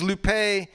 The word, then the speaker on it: Lupe: This is Lupe, L-U-P-E with the high accent on the E.